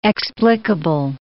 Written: explicable

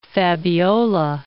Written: Fabiola

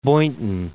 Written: Boynton Beach

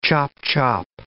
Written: chop-chop

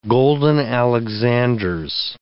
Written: golden alexanders

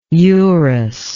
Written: eurus

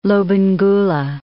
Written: Lobengula

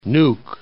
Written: Nuuk

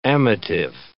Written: amative

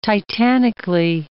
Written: titanically

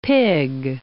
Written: pig

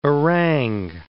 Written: orang

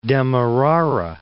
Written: Demerara

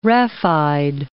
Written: raphide

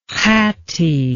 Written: Khatti

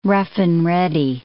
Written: rough-and-ready

